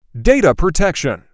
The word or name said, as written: Data protection